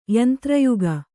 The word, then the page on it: ಯಂತ್ರಯುಗ: yantra yuga